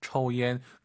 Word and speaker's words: 抽烟: 우리가 抽, 초우, 초우하지 마시고 오유는 어우 발음이에요. 抽 초옌. 그리고 정말 많은 초급자분들이 이 YAN을 딱 보시면 얀 떠올라요. 얀 아니죠. Y를 항상 마음속으로 I로 바꾸세요. IAN, EN이잖아요. – 얀. – 얀. 네. 정확한 발음은 얀, 이로 시작하는 거예요.